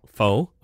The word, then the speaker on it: Phoe: And this one is spelled P-H-O-E. So is it Foe or Foe? – I would think Faux. – Faux, okay.